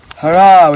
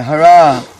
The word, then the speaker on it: Hara: Hara with a long A. Hara.